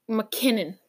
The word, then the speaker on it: MacKinnon: Why can't I pronounce his name? Nathan Mac MacKinnon. I always say McMinnon. Don't even ask. Like it's just one of those things. I always say McMinnon's MacKinnon. It sounds exactly the same, but he that guy's really good.